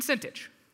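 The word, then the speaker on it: Syntyche: Now, I'll be honest, if you look at that and you're like, that's not how you say it, once you get a microphone, feel free to, pronounce those however you want. Until then, it is Iota and Sintich, to be of the same mind in the Lord.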